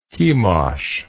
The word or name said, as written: chemosh